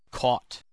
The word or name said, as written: caught